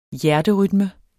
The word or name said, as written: hjerterytme